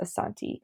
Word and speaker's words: Asante: So it's spelled Ashanti, but I believe it's correctly pronounced Asante.